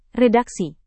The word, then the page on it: redaksi: Indonesian